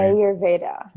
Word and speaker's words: Ayurveda: Ayurveda, I'm sorry. – Ayurveda.